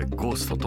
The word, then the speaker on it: 亡霊: ゴーストえー、夏の亡霊と書いてゴーストと読みます